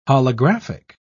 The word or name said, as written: holographic